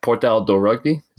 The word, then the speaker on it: Portal do Rugby: My Portuguese is terrible, but Portal do Rugby, is that correct? – Yep.